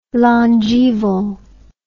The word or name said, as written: longeval